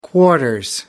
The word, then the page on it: quarters: British English